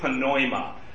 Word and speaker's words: pneuma: It's in Greek it's spelled P-N-E-U-M-A. They have no silent letters. So if you're a Greek speaker, you pronounce all the letters. So you say pneuma, versus in English we would just say pneuma, or pneumatic, or pneumonia.